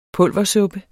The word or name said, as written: pulversuppe